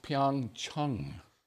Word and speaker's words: Pyeongchang: Pyeongchang. Which is actually supposed to be pronounced Pyeongchang.